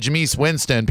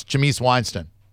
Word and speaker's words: Jameis Winston: You know, if you said Jameis Winston, people would make fun of you. It's Jameis Winston.